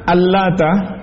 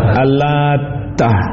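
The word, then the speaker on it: Al-Lat: Mayoritas ulama membaca Al-Lata taknya tanpa tasydid. Ibnu Abbas, Ibnu Zubair, Mujahid dan yang lainnya membaca dengan ditasydid. Yaitu bagaimana bacanya kalau ditasydid? Al-Lata. Taknya ditasydid.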